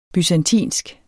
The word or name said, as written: byzantinsk